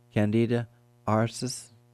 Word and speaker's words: Candida auris: And to say that is pretty much the sound it makes, Candida auris.